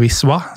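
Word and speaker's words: Wisła: og er fan av Wisla, eller Wiswa, som det uttales.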